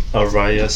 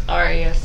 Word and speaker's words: Ariya: I'm here with Ariya Sloane. Am I saying that correctly? – It's Ariya. – Ariya Sloane. Ariya Sloane. – Like the hotel.